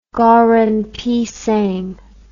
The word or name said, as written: goreng pisang